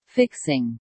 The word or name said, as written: fixing